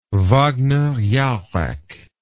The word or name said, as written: Wagner-Jauregg